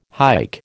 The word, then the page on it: hike: American English